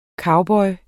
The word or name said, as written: COWBOYEN